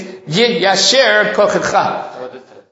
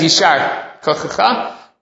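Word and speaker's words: yiyasher koach: Right, the proper way to pronounce it in the, when someone gets an aliyah, somebody does something, is yiyasher kochecha. Right, I know there's a Masoret Sasha, it says yishar kochecha. But the proper pronunciation, probably grammatically in Hebrew, should be Yiyasher Kochecha, probably the proper Hebrew.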